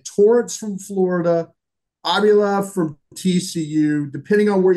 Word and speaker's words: Avila: But Torrance, I learned how to say this name. It's Avila.